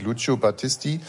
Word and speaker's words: Lucio Battisti: Es gibt es Lucio Battisti, Il mio canto libero. – Warte mal. Also ich finde, dass es relativ gut ausgesprochen hat. Der heißt wirklich Lucio Battisti.